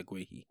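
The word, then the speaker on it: Guéhi: Gwehi? I don't know how to pronounce it. Guehi. Guehi. Yeah, Guehi. Add Eze to the mix.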